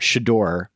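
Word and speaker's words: Shedeur: I don't know if you can see in our shared Google Doc here that I have phonetically spelled out Shador because I did not want to go Globe Tomato on this kid's first name. Shador Sanders.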